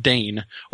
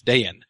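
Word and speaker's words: Dáin: So when you have somebody whose name is D-A-I-N with an accent over the A, he's doing that to indicate that you're supposed to pronounce that as two syllables. It's not just Dane or Dine. It's two syllables. It's Dayan. And similarly, G-L-O-I-N is not Gloin.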